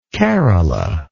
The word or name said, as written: carola